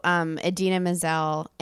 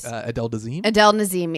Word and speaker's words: Idina Menzel: So Idina Menzel and Adele Nazeem.